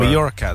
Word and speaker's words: Mallorca: Mallorca, that's how you say it.